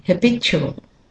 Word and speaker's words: habitual: Habitual. Habitual. Now, this word can be said two different ways. Habitual or habitual. Habitual, leaving out a syllable altogether. But either way, the b is the stressed syllable.